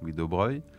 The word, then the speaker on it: Louis de Broglie: Donc ça s'écrit de Broglie, ça se prononce Louis de Broglie.